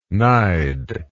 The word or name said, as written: nide